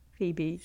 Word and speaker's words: Phoebe: It's a silent O. The amount of people that message me and get the E and the O around the wrong way, it's O first. O E. Silent O, Phoebe.